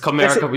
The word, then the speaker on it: Comerica: Yes, Comerica. You got it right this time. You said it right this time. No, he usually goes Comerica.